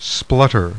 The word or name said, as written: SPLUTTER